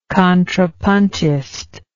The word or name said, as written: contrapuntist